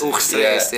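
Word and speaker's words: Oegstgeest: Yeah, and I lived in a small place called Oegstreest. That's next to Oegstreest. – Oegstreest, yeah.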